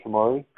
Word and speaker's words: Shamari: Am I saying it right, Shamari? – Yes, Shamari. Mm-hmm.